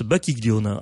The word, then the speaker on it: Bacchiglione: Donc j'ai bûché avant de venir parce que ça s'écrit Bacchiglione. Oui. Et ça se prononce Bacchiglione.